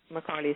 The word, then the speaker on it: Macaulay: Macaulay is spelled with an A-Y.